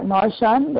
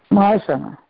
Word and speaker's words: smashana: Is it something like Marshan, or how do you say it? – Smashana.